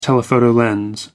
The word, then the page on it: telephoto lens: American English